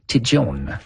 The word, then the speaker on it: Tête Jaune: Et là, pour avoir passé plusieurs vacances à Vailmount, qui est tout près de Tête Jaune, là-bas, on l'appelle Tijon. Alors, la prononciation est un peu différente dans ce secteur-là.